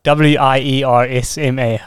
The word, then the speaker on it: Wiersma: It's basically your name, one word, Piet, and then W I E R S M A.